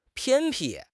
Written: 偏僻

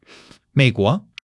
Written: 美国

